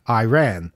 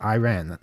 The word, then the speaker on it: Iran: I guess that's how you're supposed to pronounce it, because they spell it E-Y-E space R-A-N. So I guess that's, they're trying to tell us how, CNN here is trying to tell us how to pronounce it, Iran, not Iran.